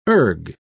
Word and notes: erg: British English

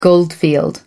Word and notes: goldfield: American English

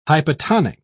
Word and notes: hypotonic: British English

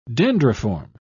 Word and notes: dendriform: British English

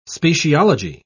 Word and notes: speciology: British English

